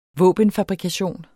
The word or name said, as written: våbenfabrikation